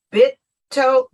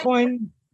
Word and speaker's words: Bitcoin: but what is the difference between Bitco and crypto? – Explain. Bitcoin.